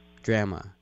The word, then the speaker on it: drama: I would simply advise you to use the American and or British pronunciation of the word, D-R-A-M-A. – Drama. – No. Drama. – Drama. – Yeah. You will just throw them from the roller coaster if you say drama.